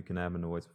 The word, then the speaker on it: cannabinoids: I just wrote down a few cannabinoids before I got in here.